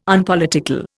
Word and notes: Unpolitical: Indian

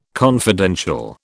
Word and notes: confidential: British English

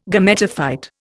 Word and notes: Gametophyte: Indian

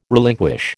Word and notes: relinquish: American English

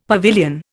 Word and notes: pavilion: Indian

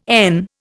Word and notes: n: Indian

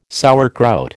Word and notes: sauerkraut: American English

